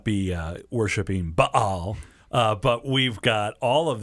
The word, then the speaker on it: Baal: And it's properly pronounced with a glottal stop in the middle, Baal.